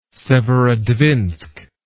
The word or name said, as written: Severodvinsk